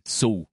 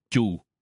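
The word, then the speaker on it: chù (畜): Chữ súc này vào thời xưa thì đọc là xù. Liều xù. Nhưng về sau thì danh từ liều xù này được đọc thành chù. Liều chù. Mặc dù bây giờ đọc liều chù, nhưng vẫn có người đọc là liều xù. Nếu có người đọc là liều xù thì chúng ta cũng đừng có nói người ta đọc sai.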